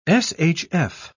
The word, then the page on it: shf: British English